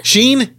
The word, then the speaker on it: Shein: And then the Shine? – Sheen? Yeah, okay, Sheen.